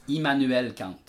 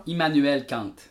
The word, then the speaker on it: Immanuel Kant: Immanuel Kant. Emmanuel? Ouais, ben c'est allemand. C'est Emmanuel allemand. Emmanuel ouais. Kant. Ouais. Dis-moi les voix. Emmanuel Kant.